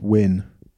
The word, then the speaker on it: Nguyen: I think it's pronounced Win.